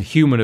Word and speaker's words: HVMN: And Human, of course, HVMN is the way that it's spelled.